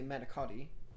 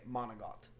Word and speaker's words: manicotti: We have, for you northern Italian folk, you say manicotti. For us southern Italian folk, we say monogot. We change our C's to G's and just don't say the last syllable.